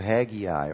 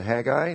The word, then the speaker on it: Haggai: The book of Haggai, or if you prefer to say it Haggai, they're both correct pronunciations.